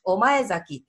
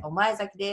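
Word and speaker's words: 御前崎: お前崎って読むんだ。さっきのやすね。すいません、読めなくって。お前崎です。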